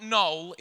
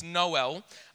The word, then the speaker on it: Noel: And it's not Noel, it's Noel. It's not Shannon Noel, who was robbed of his 2003 title. It's Noel. That's how it's pronounced.